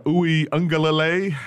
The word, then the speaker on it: Uiagalelei: Well, we'll see if uh, Uiagalelei, I think I said his name right no, it's there.